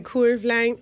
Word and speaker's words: Curveline: O sea, se escribe Curveline Line para escribe Se escribe Curveline, claro está, pero se dice Curveline Spa y Estética.